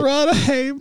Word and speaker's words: Radhames: R-A-D-H-A-M-E-S. How would you say that? – Rada Hames.